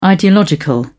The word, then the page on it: ideological: British English